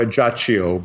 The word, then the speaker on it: Ajaccio: Ajaccio, is that the town you're referring to? – Yes. – Yes, okay. – Or Ajaccio, because if it's an Italian mm-hmm. pronunciation.